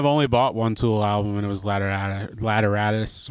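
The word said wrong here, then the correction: Lateralus: whatever, how do you say it? – Lateralis. – Yes, Lateralis. Yeah, as I like Schism so much.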